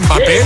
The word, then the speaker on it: Mbappé: Mbappé, Mbappé, en claro, claro. – Es que no estaba segura de cómo se pronunciaba. – No, no, lo has dicho perfecto.